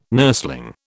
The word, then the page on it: nurseling: British English